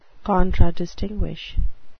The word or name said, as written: contradistinguish